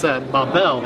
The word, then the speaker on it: Babel: And it's Babel. They actually stress the second part. It's almost identical to the way the French say it.